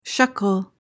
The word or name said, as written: shekel